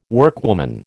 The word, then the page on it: workwoman: American English